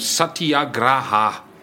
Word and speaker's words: Satyagraha: And I must just check the pronunciation, even went on to my Oxford Dictionary to get the pronunciation of Satyagraha is the word that you use for his type of resistance.